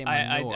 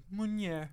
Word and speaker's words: Meunier: I cannot, for the life of me, read that word and say it correctly. I always say manure. – I did Google. – Manure. That's what I said. – I Googled it, and I hit the thing, and it's like, Meunier.